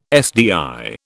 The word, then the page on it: SDI: British English